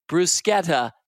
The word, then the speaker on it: bruschetta: And that, by the way, is how it is supposed to be pronounced. That's how the Italians pronounce it, bruschetta.